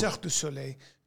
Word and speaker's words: Cirque du Soleil: It's pronounced Cirque du Soleil. Do you know what Cirque du Soleil means? – Circus of the Sun.